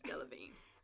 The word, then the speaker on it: Delevingne: Is that how you say her name? I can never say it properly. – I think I'm 93% sure it's Delevingne.